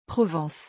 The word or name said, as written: provence